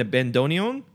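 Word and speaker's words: bandoneón: And it's got a piano and a bandoneon? – Yeah.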